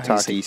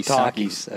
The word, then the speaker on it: Takis: How do you say tacky? I don't know. – Takis. – Takis. Okay.